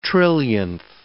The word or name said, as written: trillionth